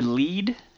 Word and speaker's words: Lead: this is near Lead? Is that how it's called? – Lead. Yeah, you pronounce it Lead because it means a lead into the vein.